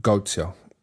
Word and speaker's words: Goethe: sent me a poem by Goethe. That's how I think is pronounced. It's spelled G-O-E-T-H-E, but supposedly it's Goethe or Goethe.